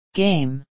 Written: game

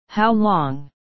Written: how long

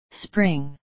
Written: spring

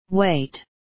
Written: wait

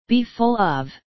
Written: be full of

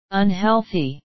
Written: unhealthy

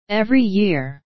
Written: every year

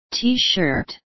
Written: t shirt

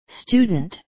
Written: student

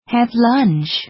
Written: have lunch

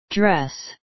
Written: dress

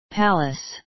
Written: palace

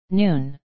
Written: noon

